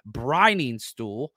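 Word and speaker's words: Briningstool: Brenningstuhl.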